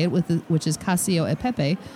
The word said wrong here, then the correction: Cacio e Pepe: And she says, I know this has been a topic of discussion before, so for the record, it's pronounced Cacio e Pepe. – Yeah, we're never going to remember that. No. – Cacio e Pepe is how it comes out.